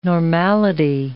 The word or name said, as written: normality